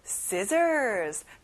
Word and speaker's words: scissors: Next, scissors. 가위예요. 친구들, when you use scissors, of course, you have to be careful. 위험해서 아주 조심해야 돼요. 그리고 가운데 C가 들어가는데 그 발음이 안 나요. 신기하죠?